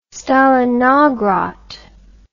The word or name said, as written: stalinogrod